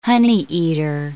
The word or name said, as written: honeyeater